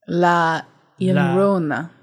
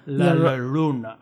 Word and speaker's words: La Llorona: La Llorona. – La Llorona. – LL is a Y sound. Llorona.